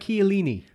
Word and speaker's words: Chiellini: Chiellini. – Correct.